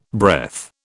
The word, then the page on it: breath: British English